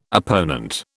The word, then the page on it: opponent: British English